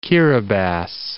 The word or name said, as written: Kiribati